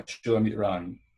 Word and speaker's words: Shulamit Ron: Am I pronouncing this right, Ron? – Yeah, Shulamit Ron.